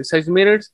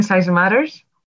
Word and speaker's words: Size Matters: Este, tienes un canal de YouTube, eh, Size Matters, que ya es un canal. Creo que eres la única persona que pronuncia bien Size Matters.